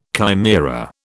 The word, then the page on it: chimaera: British English